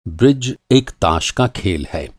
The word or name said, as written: ब्रिज